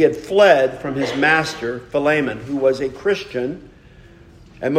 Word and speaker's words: Philemon: Some people pronounce it Philemon. I pronounce it Philemon.